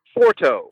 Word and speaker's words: Forto: Forto.